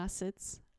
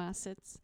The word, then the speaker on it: acid: To są aminokwasy, czyli po angielsku amino acids, amino acids, bo wiem, że bardzo dużo Polaków wymawia kwas, kwasy jako acid, ale to jest acid, czyli amino acids.